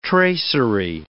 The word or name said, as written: tracery